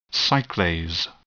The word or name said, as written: cyclase